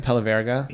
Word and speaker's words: Pelaverga: Um, Barbera d'Alba, you, you know, you know, now there's Langhe and Pella Vargas. Am I pronouncing it right? yep, Pelaverga.